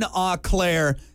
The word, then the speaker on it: Eau Claire: Clearwater Meats in Eau Claire.